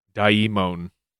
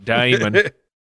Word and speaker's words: daimon: It is literally a diphthong in the word daimon, not daimon.